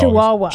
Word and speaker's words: Chihuahua: And is it Chichawa? I always get that wrong. Chichawa? How do you say it again? Chichawa. What's the dog name? You know what the dog Chihuahua. – Is. Chihuahua. That's right.